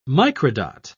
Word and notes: microdot: British English

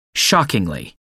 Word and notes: shockingly: British English

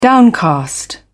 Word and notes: downcast: British English

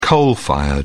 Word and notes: coal-fired: British English

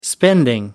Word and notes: spending: American English

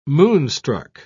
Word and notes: moonstruck: British English

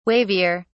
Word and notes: wavier: British English

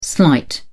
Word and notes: slight: British English

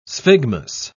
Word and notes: sphygmus: British English

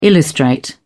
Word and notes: illustrate: British English

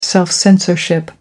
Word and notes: self censorship: American English

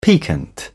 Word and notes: piquant: British English

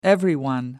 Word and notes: everyone: American English

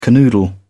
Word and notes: canoodle: British English

